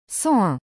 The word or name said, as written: 101